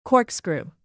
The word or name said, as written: corkscrew